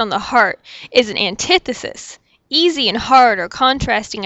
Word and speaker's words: antithesis: It's pronounced antithesis, not antithesis.